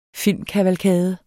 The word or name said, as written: filmkavalkade